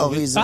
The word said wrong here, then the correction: Orelsan: Ah, Orelsan. – Orelsan. – Horizon. – Yeah. Horizon means horizon, so it was a bit weird.